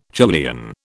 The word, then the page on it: Julian: British English